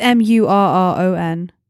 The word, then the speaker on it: Murron: It's Murron.